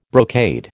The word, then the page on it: brocade: American English